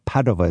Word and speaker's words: Padova: Oh, but Padova is how you say it.